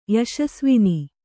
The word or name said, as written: ಯಶಸ್ವಿನಿ